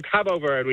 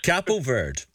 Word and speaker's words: Cabo Verde: What's the official pronunciation? – So Cabo Verde, we've been told. – Cabo Verde.